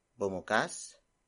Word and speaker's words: bumukas: Bumukas ang pinto ng bahay. Bumukas ang pinto ng bahay.